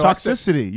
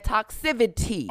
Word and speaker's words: toxicity: No, you said it right. No, toxicity, you said. – No, but she said, what did you say? – He said toxivity with a V.